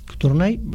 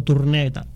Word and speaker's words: Tournai: Tournai, curiosamente, Tournai, lo digo como es, no digo Tournai, Tournai, como se escribe.